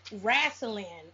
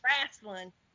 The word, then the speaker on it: wrestling: Wrestling is a southern way of, it's a southern term. – You said it wrong. It's wrestling. – Oh, yeah, Jesus. – It's wrestling.